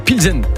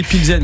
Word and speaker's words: Pilsen: Je crois qu'on dit Pilsen, mais ça s'écrit P-L-Z-E-N.